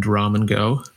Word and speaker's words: Ramen Girl: There's a few artists and collaborations on the album that are from like the Asia region, including Syrup and Ramen Girl. I might be mispronouncing that second one. – That's right.